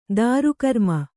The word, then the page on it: ತ್ರಿಕರ್ಮಿ: kannada